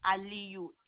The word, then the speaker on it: Aliyu: Okay, last name is Aliyu, A-L-I-Y-U. – L-A-Y-A-U? – A, as in apple.